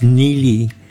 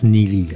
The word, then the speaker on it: Neagley: It's Neely. – Neely. – Yeah, it's spelled N-E-A-G-L-E-Y, but actually it's pronounced Neely.